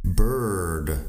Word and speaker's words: bird: Y ahí tenemos bird. Bird en inglés americano sí que pronunciamos la R, r así que bird. Diferente a cualquier sonido en español.